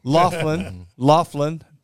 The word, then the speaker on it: Laughlin: And so it's great to have David McLaughlin, am I pronouncing that right? – No Mick, just Laughlin. – Laughlin.